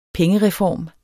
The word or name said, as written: pengereform